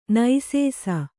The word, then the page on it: ನುಸಿಸು: nusisu